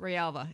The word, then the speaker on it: Grijalva: His name is Raul Rialva, and his last name is actually spelled G-R-I-J-A-L-V-A, but I looked it up. It's pronounced Rialva.